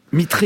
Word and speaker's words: Mitre: Mitre, il faut prononcer, pardon. – Oui, c'est comme ça qu'on l'appelle. Très bien, vous avez raison de me corriger.